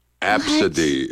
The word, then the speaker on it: Abcde: Absidy is the name. Absidy. It's spelled A-B-C-D-E.